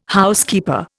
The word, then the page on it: Housekeeper: Indian